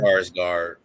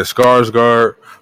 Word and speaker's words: Skarsgård: Skarsgård. – Yeah, Skarsgård.